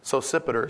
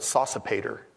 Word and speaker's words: Sosipater: Timothy, my fellow worker, and Lucius, Jason, and Sosipater, or, you know, you can say anything you want with that name, but Sosipater is what my books say how to say it.